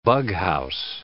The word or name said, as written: bughouse